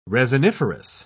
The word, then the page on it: resiniferous: British English